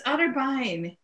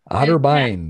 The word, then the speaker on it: Otterbein: It's Otterbein. – Otterbein.